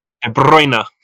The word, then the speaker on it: De Bruyne: De Bruyne.